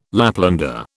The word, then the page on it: Laplander: British English